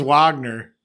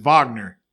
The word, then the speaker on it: Wagner: You say it different. It's Wagner. – Wagner. – No, Wagner. – Yeah. – Because he's German. – The W is like a V. – All right, so Wagner from Michigan got drafted.